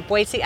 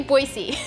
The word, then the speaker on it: Boise: Convention and Visitors Bureau in Boise, Idaho.